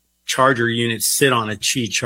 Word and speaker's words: Qi: But, and also pronouncing it because it's spelled QI. So it's like, right. Everyone says Qi, Qi. Yeah. And it's like, that's Qi.